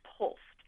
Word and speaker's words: POLST: It's an acronym, P as in Paul, O-L-S as in Sam, T. It's pronounced POLST, and it stands for the Physician Order for Life-Sustaining Treatment.